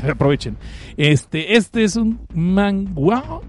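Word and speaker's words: manhwa: La siguiente obra, porque no es un manga, es un manguo, manguo y lo dije bien, porque este es, eh, es de los Kansahamida, sí, es, es Okaseo, Hanaseo, este es eh, un, manga coreano.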